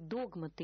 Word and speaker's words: догматы: Посвященные, знающие, понимающие христианские догматы, я произнесу это слово именно с таким ударением, потому что именно это ударение является единственно верным.